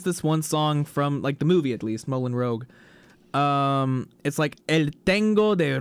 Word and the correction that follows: Moulin Rouge: Wait, you mean Moulin Rouge? – Moulin Rouge, that's what I mean. Okay. Is that how you're supposed to pronounce yes. – it? – Yes.